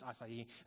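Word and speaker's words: açaí: Is it acai? Is it a- acai? It's acai.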